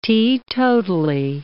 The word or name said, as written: teetotally